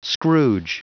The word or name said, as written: scrooge